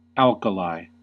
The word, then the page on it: alkali: American English